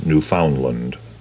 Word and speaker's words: Newfoundland: And by the way, that's the way the locals pronounce that province, Newfoundland.